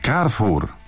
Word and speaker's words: Carrefour: Poprawnie należy wymawiać nazwę francuskiej sieci super i hipermarketów Carrefour. Należy przy tym akcentować ostatnią sylabę słowa, pisze słuchacz. Zgodnie z zasadami wymowy C przed samogłoską czytamy jako K, a O, U jako U. E jest nieme.